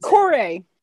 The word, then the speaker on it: Kore: Okay, so Persephone's name was Core. K-O-R-E. At least that's how I pronounce it. – Core.